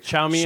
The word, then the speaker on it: Xiaomi: And I'm, you know, talking mainly about Huawei here, but even companies like Xiaomi. – Mm-hmm. – Did I say that right? – Xiaomi.